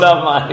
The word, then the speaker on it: Armani: Armani.